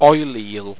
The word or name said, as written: oilele